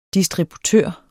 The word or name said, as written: distributør